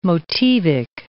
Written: motivic

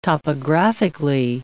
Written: topographically

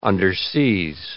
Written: underseas

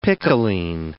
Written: picoline

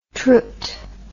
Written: trud

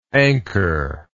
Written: anchorlike

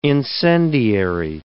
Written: incendiary

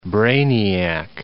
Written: brainiac

